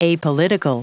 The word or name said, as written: apolitical